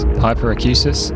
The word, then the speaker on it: hyperacusis: You can develop something called hyperacusis. I think I've pronounced that correctly. Let me check. Yes, hyperacusis, which is a severe sensitivity to kind of everyday sounds.